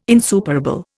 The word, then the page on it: insuperable: Indian